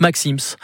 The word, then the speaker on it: Maxim's: Le Fouquet devient Fouquet's et chez Maxime devient et se prononce Maxim's.